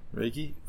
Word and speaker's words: Reiki: Um, I looked up a, I, can't pronounce it, R E I K I. Reiki? Reiki? Reiki? Reiki? Oh, that, that sounds right.